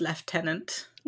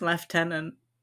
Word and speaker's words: lieutenant: In the British pronunciation, it's lieutenant. – What? – Did you not know that? – No. – It's spelled the same, but pronounced lieutenant.